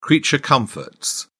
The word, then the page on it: creature comforts: British English